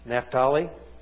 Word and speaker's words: Naphtali: Naphtali. Okay. Don't usually pronounce it that way, but yeah, that'll work.